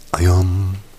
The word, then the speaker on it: Ayam: Ich würde das auf der zweiten Silbe betonen. Wie ist es denn richtig? – Ayam.